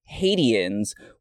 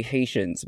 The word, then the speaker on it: Haitians: In an early scene, she mispronounces Hadians, which was, in fact, an Alicia Silverstone error that Amy Heckerling very quickly had to be like, nobody tell her how to say Haitians,